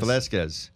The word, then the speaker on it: Velasquez: The U. Velasquez. – Velasquez. – There you go. – There it is.